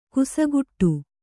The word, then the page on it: ಕುಸಗುಟ್ಟು: kusaguṭṭu